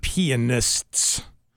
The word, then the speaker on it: pianists: I tried to pronounce it properly. – Good. – Pianists.